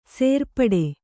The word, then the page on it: ಸೇರ್ಪಡೆ: sērpaḍe